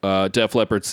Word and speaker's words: Def Leppard: There are some real hard rockin' bangers on this LP / Leppard / Def Leppard, Def Leppard's Hysteria. – Thank you for saying it properly.